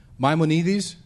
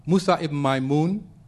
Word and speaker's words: Maimonides: It's ironic that two of the greatest writers of Andalus, one of Jewish heritage, another an Arab, Maimonides, many of you have heard this name, that's just a bad pronunciation for Musa ibn Maimun, and Averroes, which is another very bad pronunciation for Ibn Rushd.